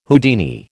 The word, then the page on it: Houdini: American English